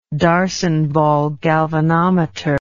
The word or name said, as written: darsonval galvanometer